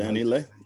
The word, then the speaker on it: Dani Leigh: That is exactly Dani Leigh.